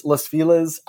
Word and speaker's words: Los Feliz: Los Feliz, I'm just going to say that because that's what people have said to me when I've said it other ways.